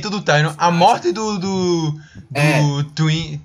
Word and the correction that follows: Tywin: Twin, Twin, não sei como é que fala, Twin. É Twin mesmo, Jamie? É, é Tywin, Tywin. Tywin, Tywin.